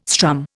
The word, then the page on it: strum: Indian